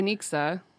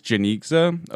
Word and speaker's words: Janicza: Yes, because we'll need some help with her name pronunciation, because she has, it's like Janixa. – It's Janixa.